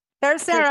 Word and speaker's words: Terceira: We just talked about Terceira. Did I say that right? – Debbie, yes, did. – Terceira.